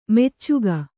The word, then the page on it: ಮೆಚ್ಚಿಕೆ: kannada